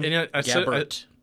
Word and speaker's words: Gabbert: But still, it's like, how do you pronounce his last name? – Gabbert. – Gabbert. – Yeah, there's no Rhett.